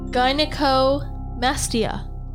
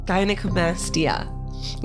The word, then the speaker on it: gynecomastia: Gynecomastia. – Gynecomastia.